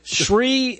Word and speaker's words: Shri: Shri Thanadar. Shri Thanadar. I think I got it. – What? Who again? – Shri. That's the way it's spelled. S-H-R-I. S-H-R-I.